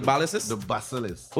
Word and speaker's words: basilisk: The basilisk? – The basilisk.